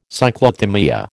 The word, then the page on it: Cyclothymia: American English